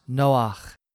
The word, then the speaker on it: Noah: Noah in Hebrew is pronounced Noach, meaning both rest and comfort.